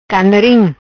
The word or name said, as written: Canarim